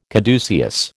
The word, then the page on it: caduceus: American English